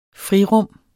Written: frirum

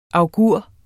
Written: augur

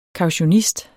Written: kautionist

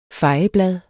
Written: fejeblad

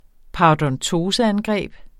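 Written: parodontoseangreb

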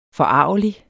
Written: forargelig

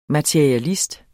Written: materialist

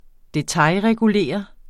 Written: detailregulere

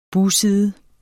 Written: bugside

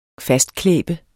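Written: fastklæbende

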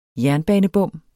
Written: jernbanebom

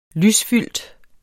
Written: lysfyldt

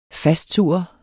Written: fastsurre

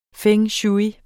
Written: feng shui

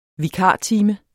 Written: vikartime

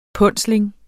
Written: punsling

